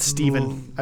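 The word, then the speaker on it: Stjepan: Ron Mars and Shippon Sajic. – Yeah. – When they were doing it. – It's Steven. I found out. That's how you pronounce it.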